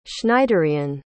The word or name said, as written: Schneiderian